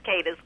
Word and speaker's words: Cadiz: Calling us from, I'm not sure how this is pronounced, Cadiz, Kentucky? – It's Cadiz, Kentucky.